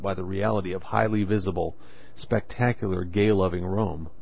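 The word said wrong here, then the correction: Röhm: Rum is how it's actually pronounced.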